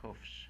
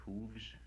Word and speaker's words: hoofs: On his hoofs. Should that be pronounced hooves? – Both ways, and the hoofs is the preferred form here.